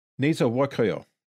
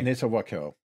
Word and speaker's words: Nezahualcóyotl: Nezahualcóyotl. – Oh, is that how you say it? Okay. – Nezahualcóyotl.